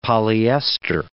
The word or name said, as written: polyester